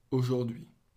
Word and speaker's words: aujourd'hui: Aujourd'hui. – So aujourd'hui. Oui, three syllables, today.